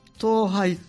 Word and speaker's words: 登拝: と言いますのは、ガッサン、表ガッサンの特徴なんですけども、はい、ちょっと復習を兼ねてお話ししますとね、ガッサンを登るときは登山と言わずにね、はい、登拝と言うんですが、うん、登る拝むと書いて登拝、はいはい、と言うんですね。